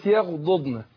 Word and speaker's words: يغضضن: احنا عندنا كلمة يغضضن، ضاد وضاد، يعني الضاد مكررة،